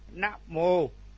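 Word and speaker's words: namo: Rồi chữ mờ o mo, nếu chúng ta đọc là tiếng Việt là mo, nhưng mà tiếng Pali chữ o là đọc là ô, là mô. Nam mô, nam mô, nam mô, nam mô, Nam Mô.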